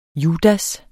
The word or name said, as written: judas